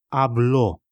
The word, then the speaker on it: habló: Él habló. Заметил, как меняются ударения и окончания? В испанском очень важно их не проглатывать. По окончанию и по ударению слова, глагола, определяется не только время, но и лицо.